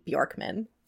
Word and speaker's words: Bjorkman: I'm pretty sure it's Katie Bjorkman. – What if this is the one time that you're actually supposed to pronounce the J? – Could you imagine?